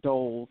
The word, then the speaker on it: Doles: So if anybody wants to go out there, and I'm on, my name is Sam, but it's spelled backwards. It's Doles L. Sam, D-O-L-E-S, Doles L.